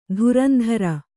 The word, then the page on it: ಧುರಂಧುರಿ: dhurandhuri